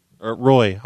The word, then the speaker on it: Roy: Did you say your name's Ray? – Roy, R-O-Y.